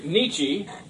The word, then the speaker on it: Nietzsche: And there was a guy named Friedrich Nietzsche, kind of a funny, you know, kind of rhymes with peachy.